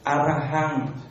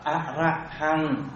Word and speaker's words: Arahant: À, cái chữ số 1 đọc là Arahant. – Arahant, không phải A ấy, chữ này đọc là Arahant.